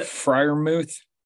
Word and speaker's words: Freiermuth: I know the player, I just can't pronounce the dang name, but the Freiermuth.